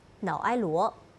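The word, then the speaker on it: Naoero: 瑙鲁政府此前表示，瑙埃罗这一名称更能体现瑙鲁的民族遗产、语言和身份认同。而现行国名瑙鲁源于外国语言，难以准确的发音瑙埃罗。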